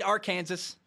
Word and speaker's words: Arkansas: It's spelled Arkansas, but I have to spell S A W at the end because I forget how to say it. I want to say Arkansas all the time.